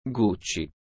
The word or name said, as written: Gucci